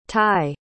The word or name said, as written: ty